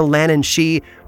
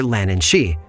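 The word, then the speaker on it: leannán sí: It makes one wonder if something like writer's block was once thought of as an artist losing their Lannan she. Meanwhile, on the Isle of Man, a very similar creature has been written about for generations. It's spelled differently but pronounced the same, Lannan she, although this creature is far more dangerous than her Irish counterpart.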